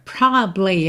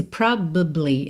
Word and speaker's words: probably: Olha ela aqui de novo, a palavra probably, a palavra de três sílabas que só soam duas.